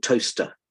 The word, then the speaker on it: Towcester: we settled in a little town in Northamptonshire called Towcester, which is spelt Towcester.